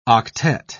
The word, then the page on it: octette: British English